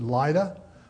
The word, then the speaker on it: Lydda: I pronounce it Lydda because I love that song, Lydda Rose, from Music Man. Lydda, so it's Lydda.